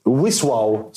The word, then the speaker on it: Wisła: Ja, jag var på Legia mot eh, Wisław, som de tydligen uttalas.